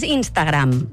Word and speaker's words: Instagram: és Instagram. – La, segur, eh? – Sí, la pronúncia Instagram recomanada en català és aguda.